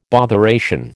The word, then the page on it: botheration: American English